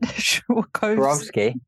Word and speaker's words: Swarovski: Swarovski.